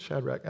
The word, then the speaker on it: Shadrach: Somebody thought I was mispronouncing Shadrach when I said Rad Shack. I'm like, I think it's Shadrach. I know.